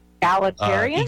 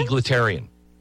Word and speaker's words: egalitarian: How do you say this word? Sound like such a moron all the time. – Egalitarian? – Egalitarian. Yes, egalitarian. – Thank you.